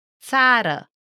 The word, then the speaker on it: Țara: It's the 25th letter, and you can say it like the ZZ in pizza, like in this word. – Țara, Țara which means country.